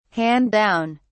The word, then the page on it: hand down: British English